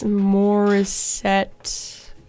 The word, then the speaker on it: Morissette: You just heard Michael Bublé, Feeling Good, Big Yellow Taxi, and All I Really Want by Alanis Morissette.